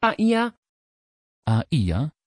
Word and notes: Shaila: Dutch

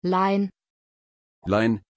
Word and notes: Lyne: German

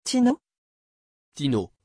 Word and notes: tino: French

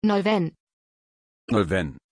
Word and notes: Nolwenn: German

